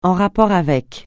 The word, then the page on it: en rapport avec: français